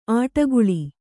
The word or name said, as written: ಆಟಗುಳಿ